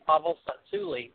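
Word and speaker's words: Pavel Tsatsouline: It's how you say his name, Pavel Satsuli.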